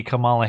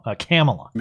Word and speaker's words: Kamala: And then eventually Kamala Harris comes on. – When I agreed to serve as President Obama's running... – Oh, sorry. First, Joe Biden mispronounced her name. This is where he called her Camel-a.